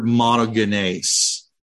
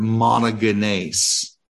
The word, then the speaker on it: monogenes: Finally, let's talk about the Greek word monogenes. And that's how that's pronounced. I know it looks like monogenes, you know, like somebody only has one set of genes in their DNA structure, but this is sort of a transliteration into English of a Greek word monogenes.